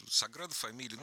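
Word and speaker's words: Sagrada Família: Саграда фамилия, вот сам собор, вот этот сейчас у меня вылетел из Нет, нет, почему вы правильно сказали, Саграда фамилия.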